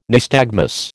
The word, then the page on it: nystagmus: American English